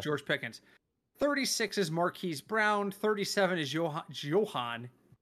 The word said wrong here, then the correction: Jahan: How do you say Dotson's first name? Is it Johan? I have Jahan. Is it Jahan? – Jahan, okay.